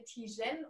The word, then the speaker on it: Tijen: Ich habe wirklich, sage ich jetzt direkt zum Anfang, ich habe immer Angst, dass ich deinen Vornamen falsch ausspreche. Herzlich willkommen, Tijen. – Vielen Dank, liebe Nora. Und äh, du hast es richtig gemacht.